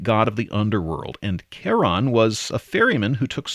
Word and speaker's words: Charon: NASA says Christie also liked the fact that the first four letters were the same as in his wife's name, Charlene, and that's why he pronounced it Charon.